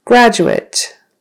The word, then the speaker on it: graduate: Graduate. So that's, the emphasis is on the first syllable. Graduate. That's if it's a noun.